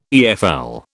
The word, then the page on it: EFL: British English